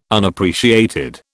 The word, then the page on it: Unappreciated: British English